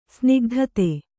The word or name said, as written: ಸ್ನಿಗ್ಧತೆ